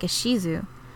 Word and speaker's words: Ishizu: Ishizu is spelled so that it is pronounced something more like Ishizu.